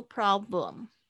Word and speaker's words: problem: 这边呢，我特别要讲的就是，我发现有两三个同学 no problem 这个 lum 会念成 room，呃，不是 r 的声音，是 l 这个 l l 这个了了了。 No problem.